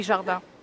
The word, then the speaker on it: Jardin: So they can just find me on my website or just Google Valerie Jardin, J-A-R-D-I-N.